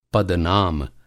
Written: पदनाम

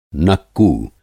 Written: नक्कू

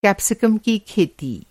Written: कैप्सिकम

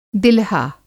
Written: दिलहा

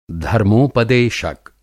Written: धर्मोपदेशक

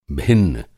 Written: भिन्न

